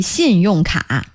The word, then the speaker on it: 信用卡: Are you using two syllables there? 信用卡啊? – No, 卡 is one syllable.